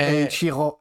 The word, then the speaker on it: Eiichiro: Eiichido, Eiichido Oda. Eiichido Ma Oda. no, Eiichiro, è... Eiichiro. Esatto, si dice, allora, in giapponese si pronuncia Eiichiro.